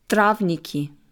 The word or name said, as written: Trawniki